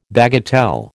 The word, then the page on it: bagatelle: American English